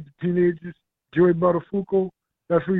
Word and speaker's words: Buttafuoco: It's like, it's pronounced Buttafuoco. – Right, yes. Buttafuoco.